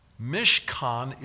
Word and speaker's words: mishkan: M-I-S-H-K-A-N is the way you can spell it. Mishkan, that's how you transliterate it. M-I-S-H-K-A-N, mishkan. The word for tent is ohel. O-H-E-L is the way we would transliterate it.